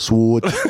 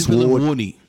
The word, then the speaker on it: sword: Yeah, I feel like Pop Smoke would be like that sword. Give me the sword. Give me an award. A sword. Give me the sword. I go hard. – That is weird, though. It's a silent W, though, right? – Yeah.